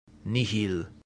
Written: nihil

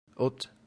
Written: ut

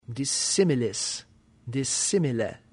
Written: dissimilis

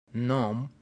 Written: num

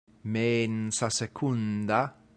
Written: mensa secunda

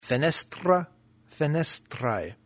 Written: fenestra

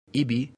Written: ibi

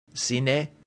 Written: sine

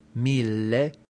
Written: mille

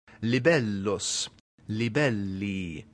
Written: libellus

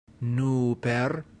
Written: nuper

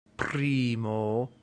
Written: primo

